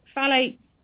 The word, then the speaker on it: phthalates: it's phthalates and then parabens. – Yep. So phthalates, they basically act like xenoestrogens.